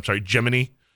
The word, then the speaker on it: Gemini: his training through the Gemini, Gemini, which is how they pronounced it, which I think is one of the many historic details they got perfectly right through the Apollo missions.